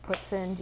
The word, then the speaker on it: poutine: So like we, Ezra likes the food known as poutine. That's actually pronounced putzin.